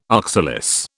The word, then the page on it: oxalis: British English